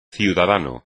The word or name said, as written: ciudadano